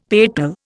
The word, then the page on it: petal: Indian